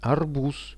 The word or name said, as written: arbuz